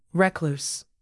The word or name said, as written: recluse